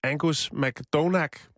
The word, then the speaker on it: Angus McDonagh: Øhm, der er en ældre herre, 64 år, englænder, øh, ved navn Angus McDonagh, tror jeg, han det udtales. Ja, det gør det.